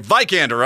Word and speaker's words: Vikander: Vikander, right? – You got it right, Vikander. Right. – Just like Viking.